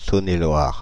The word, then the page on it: Saône-et-Loire: France (Île-de-France); [sɔn.e.lwaʁ]